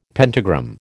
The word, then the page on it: pentagram: American English